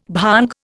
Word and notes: bhang: Indian

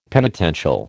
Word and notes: penitential: American English

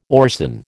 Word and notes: orcin: American English

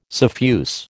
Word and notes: suffuse: American English

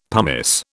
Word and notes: pumice: British English

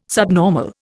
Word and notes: subnormal: Indian

